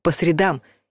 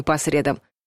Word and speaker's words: средам: Если мы имеем в виду день недели, а чаще всего так и есть, то ставим ударение на последний слог — по средам. И только так, даже если некоторые словари добавляют, что в разговорной речи допустимо и по средам.